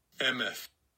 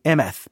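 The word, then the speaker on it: Emeth (אמת): If you look at the transliteration, I would not say Emeth, but okay, I'm glad I listened to the pronunciation, because the transliteration, I would say it differently.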